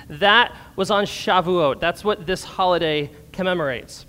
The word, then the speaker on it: Shavuot: Let me hear you all say Shavuot.